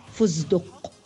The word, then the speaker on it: فستق: وقد ينطقونها دالا في كلمة فستق فيقولون فزدق محولين التاء إلى دال والسين إلى زاي